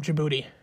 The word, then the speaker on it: Djibouti: So then I guess it would be a black jaguar from Djibouti. Because the D is silent.